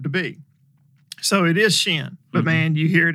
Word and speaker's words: Chêne: So and it is Shin.